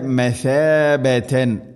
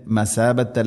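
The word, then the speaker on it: مثابة: ജഅൽന ജഅൽന ൽബൈത മസാബതൻ. മസാബത്ത് അല്ല സീനല്ല ഥ. ഉച്ചരിക്കുന്ന സമയത്ത് നാവിൻ്റെ അറ്റം മുൻപല്ലുകളുടെ ഇരുനിരകൾക്കിടയിലൂടെ അല്പം പുറത്തോട്ട് നിൽക്കണം നേരിയ തോതിൽ. മസാബത്ത് മസാബതൻ ലിന്നാസ് തൻവീന് ശേഷം ലാം ഇത് ഖംബിലാകുന്ന മണിക്കാതെ കൂട്ടിലയിപ്പിക്കണം. മസാബതൻ ലിന്നാസ് മസാബതൻ ലി ലിന്നാസി നൂന് ശദ്ദ് മണിക്കണം.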